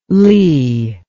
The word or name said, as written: Leigh